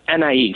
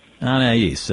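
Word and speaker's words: Anaïs: And am I pronouncing that one correctly? A-N-A-I-S? – Anais. – Anais, of course.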